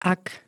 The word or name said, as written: ak